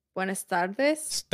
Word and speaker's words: Buenas tardes: Buenas tardes.